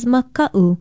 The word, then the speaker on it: maka'u: Aloha, I'm Leilani Poliahu of Hawaii Public Radio, and our Hawaiian word of the day is maka'u. Maka'u is spelled M-A-K-A-Okina-U, and it means fear, frightened, afraid, cowardly, timid, unsafe, or dangerous.